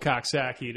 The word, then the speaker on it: Coxsackie: If you don't know, there's a town in upstate New York called Coxsackie. It is spelled just like how it sounds.